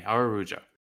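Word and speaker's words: Araújo: I think I'm gonna go with Arujo, I believe. He's Brazilian, so you pronounce the J. – Okay, Arujo.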